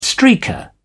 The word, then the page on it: streaker: British English